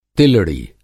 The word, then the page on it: तिलड़ी: Hindi